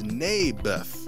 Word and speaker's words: Naboth: Mayberth. – I think you're saying it wrong. His name was Naboth.